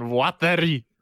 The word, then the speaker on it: Watari: Ryuk and Watari.